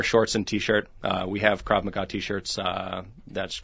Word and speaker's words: Krav Maga: it's Krav Maga, is that how it's pronounced? – Yeah, it's called Israeli Krav Maga, and what that means is contact combat.